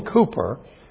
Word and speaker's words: Cowper: Written by the great William Cooper, spelled Cowper but pronounced Cooper, 250 years ago.